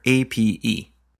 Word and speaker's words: APE: or APE. – And I think it must have been pronounced ape, because all the icons are apes.